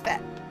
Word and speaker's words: fête: Is it French? – It is French. – It is fête.